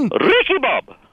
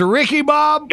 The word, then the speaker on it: Ricky Bob: This is Ricky Bob. – Beg your pardon? – Ricky Bob. – How you spell that? – R-I-C-K-Y-B-O-B. Just like it sounds. – Wait a minute. Your name is Ricky Bob? – Yes, Ricky Bob.